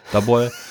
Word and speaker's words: Daboll: Brian Daboll war da ja auch noch im spricht man ihn so aus, genau. Oder Daboll oder Daboll. Daboll. ja.